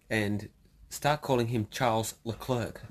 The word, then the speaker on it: Leclerc: So he actually says Charles Leclerc when everyone is like, Charles Leclerc.